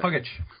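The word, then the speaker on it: Pagac: Puggich. Fred Puggich. – Oh, my gosh. Why can I not say Fred Puggich? – Because it's spelled like Paggich.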